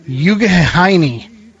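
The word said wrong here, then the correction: Youghiogheny: And that would be Yucagani.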